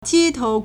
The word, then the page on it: 街头: 街頭; jiētóu